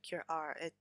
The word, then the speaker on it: Cure Art: Cure R. That would be the French pronunciation.